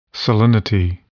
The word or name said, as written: salinity